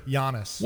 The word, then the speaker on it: Giannis: It's I like that you pronounced the G. – Mm, isn't that, don't you need to do that? – No, it's a Y sound. – Are you sure? – Giannis.